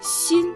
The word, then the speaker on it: 薪: «Син» произносится в первом тоне.